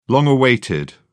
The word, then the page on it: long awaited: British English